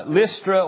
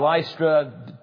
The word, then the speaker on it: Lystra: Now, it says Timothy's city is named in verse 1, and it's Lystra or Lystra. People pronounce it different ways. I always say Lystra, then I heard some say Lystra. Anyhow, you know which city we're talking about, either way you pronounce it.